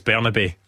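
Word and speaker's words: Bernabé: I think it's Bernabe is how it's pronounced.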